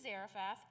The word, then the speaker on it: Zarephath: So he went to Zarephath. Zarephath, pronounced it wrong the first time.